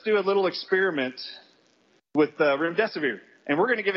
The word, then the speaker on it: Remdesivir: I actually pronounce it, your death is near. Remdesivir. If that helps you remember.